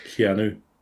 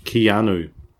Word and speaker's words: Keanu: Keanu. – You can say it. – Keanu. – Reeves and Alex Winters. – It's like canoe, but it's more pronounced.